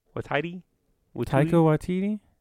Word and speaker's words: Taika Waititi: And then the IG-11, the robot, that's Takai Waititi. – Taika Waititi? – Taika. I never knew how to say his name. Thank you for saying it correctly.